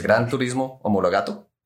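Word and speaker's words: Gran Turismo Omologato: Sí, que es Gran Turismo Homologato.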